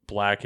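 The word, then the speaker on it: 6lack: For the last couple months, I've been on like a Black binge of, so Black is, his name is spelled Six Lack.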